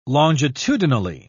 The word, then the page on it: longitudinally: British English